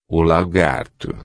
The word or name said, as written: lagarto